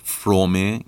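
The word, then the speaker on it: Fromm: که البته اصلش فرومه یعنی دو تا حرف سامت اول کلمه و ما در زبان فارسی نمیتونیم تلفظ کنیم یعنی میتونیم تلفظ کنیم ولی نباید تلفظ کنیم اوف.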